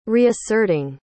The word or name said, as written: reasserting